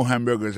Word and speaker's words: hamburger: N'a pas remarqué que dans mot hamburger, ça, comme t'as dit, il y a trois syllabes là-dedans, côté appuyé, puis plus ça, c'est sous premier syllabe là.